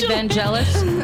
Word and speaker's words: Vangelis: Is it Vangelis or Vangelis? – People say Vangelis.